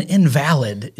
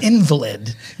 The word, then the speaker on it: invalid: it's not an invalid. It's an invalid.